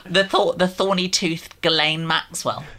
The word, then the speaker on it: Ghislaine: Oh, Ghislaine Maxwell.